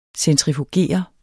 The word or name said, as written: centrifugere